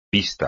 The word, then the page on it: pista: espagnol